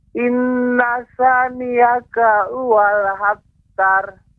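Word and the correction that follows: huwal abtar (هُوَ الْأَبْتَرُ): Ya, pengucapannya huwal abtar di situ iya, ya, terdapat ya. hamzah atau alif harus dibaca dengan jelas.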